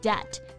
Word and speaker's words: debt: Debt, D-E-B-T 表示欠债、债务这个意思。要注意啊，这里的字母 B 是不发音的。 Debt.